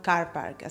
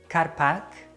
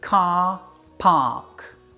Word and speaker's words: car park: Don't pronounce the R's, it's not Carpac. Lengthen those vowel sounds and pronounce it car park.